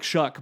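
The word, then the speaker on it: Shough: If you're out there and you haven't seen his name, it does not look like Shuck,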